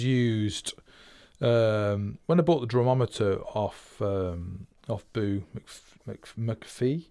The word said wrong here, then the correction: McAfee: it's McAfee, I think is the correct pronunciation.